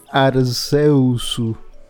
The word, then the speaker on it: Arceus: Mira, pues en japonés dice Arceusu. Así que así se pronuncia.